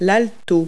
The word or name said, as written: viola